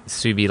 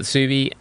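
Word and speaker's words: Tsubi: So Sukia turned into Subi, spelt with Subi, like S-U-B-I. And then Oscar said, why don't we sell it with the T? It's like Tsunami, it would be a silent T.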